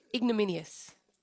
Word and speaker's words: ignominious: Ignominious. Is that right? – No. – Not ignominious. – How do you say it? – Ignominious. – Ignominious. – There's no L. – Ignominious. Yeah, remove the L.